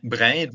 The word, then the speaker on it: Brindisi: vous étiez au Collège international Saint-Laurent de Brindes, c'est comme ça qu'on prononce? – Saint-Laurent de Brindisi, ou de Brindisi, in italiano.